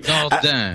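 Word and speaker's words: Dordain: Anyway, staying with France for a minute, because the European Space Agency is down there, and Director General Jean-Jacques Dardin. Thank you. – Dardin. – Let's get the pronunciation right.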